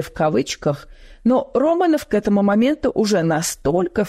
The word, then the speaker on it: Романов: Романов с ударением на первый слог оставил глубокий след в ночной жизни Лос-Анджелеса.